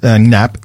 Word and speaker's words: Knapp: Alex Knapp, surname pronounced Knapp, K-N-A-P-P.